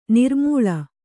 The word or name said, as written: ನಿರುಮ್ಮಳ